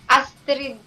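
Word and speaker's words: Astryd: Paano pag pronounce ang name mo? Astrid or Astrid? – Astrid po.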